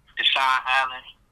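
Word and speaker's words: DeSean Allen: And then on Facebook, Deshaun Allen, you know, DESEAN space ALLEN.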